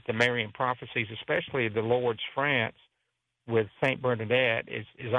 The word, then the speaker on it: Lourdes: So if you want to go there, the name of the website is Direct from Lords, and you spell that LOURDES.